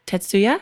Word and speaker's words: Tetsuya: You're working at Tetsuya.